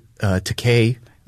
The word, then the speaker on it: Takei: First of all, I'm going to just let you know that Mr. Takei let me know that his name rhymes with I'm so gay.